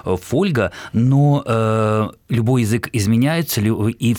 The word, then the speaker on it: фольга: И вот там сказано, что «фольга» – это польское слово, и ударение, соответственно, на первом слоге восходит к латинскому «фолиум».